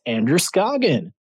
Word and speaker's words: Andrew Scoggin: It's Andrew Scoggin.